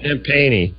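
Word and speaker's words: Champagnie: Looks like champagne, but that ain't how you say it. – I think it's Justin. – Oh. – Champagne-y.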